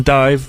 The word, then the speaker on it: DIIV: Starting out the set, then Dive. That's, of course, D-I-I-V. They have an upcoming album called Zirp. That's spelled Z-I-R-P, exclamation point.